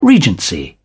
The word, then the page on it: regency: British English